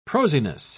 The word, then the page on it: prosiness: British English